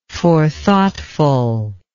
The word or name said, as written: forethoughtfulness